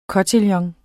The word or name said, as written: kotillon